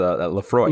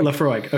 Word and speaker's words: Laphroaig: Yeah, so that's the Laphroaig. – Laphroaig, okay.